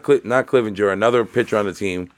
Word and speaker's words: Clevinger: So what is this pitcher, Clevinger? – Mike Clevinger? – Clevinger, okay. I wanted to make sure I was pronouncing it right.